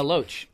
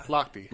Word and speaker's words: Lochte: But Loach. – Yeah. – Lochte.